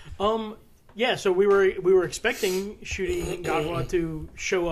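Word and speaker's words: Ncuti: I kept saying Nukti Gatwa, but it's Shuti is how it's pronounced. – Yeah, I think the N is silent, it's just Shuti Gatwa.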